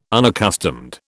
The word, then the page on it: unaccustomed: British English